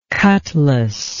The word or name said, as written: cutlass